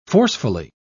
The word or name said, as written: forcefully